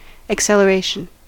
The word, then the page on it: acceleration: American English